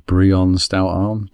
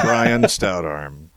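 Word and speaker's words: Brion Stoutarm: Just for all the listeners at home, they are talking about the card Brion Stout Arm, but they're pronouncing it wrong. Sorry. Carry on. – Whatever. Brian Stout Arm.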